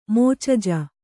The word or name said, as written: ಮೋಚಜ